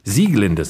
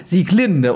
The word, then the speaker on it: Sieglinde: Sieglinde sehe ich hier gerade aus der Walküre, weil nämlich alles bei Wagner auf der ersten Silbe betont wird. Ja, also das wissen viele auch nicht. Und die alten Wagnerianer legen da sehr viel Wert drauf. – Ich mache es immer falsch. Ich mache nämlich immer Sieglinde und die Walküre.